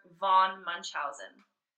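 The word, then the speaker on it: Münchhausen: This character is loosely based on the real man, and this is where I'll butcher it, Baron Hieromus Karl Friedrich von Munchausen. He was born in 1720. – That was honestly like a very impressive pronunciation of said German words.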